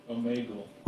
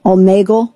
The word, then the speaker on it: Omegle: Omegle? – Omegle. – Omegle.